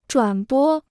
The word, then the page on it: 转播: zhuǎn bō